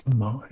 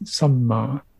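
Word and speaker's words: sammā: That's samma. It's complete. See what I mean? And it's quite it's quite interesting to when you learn how Pali is pronounced, samma.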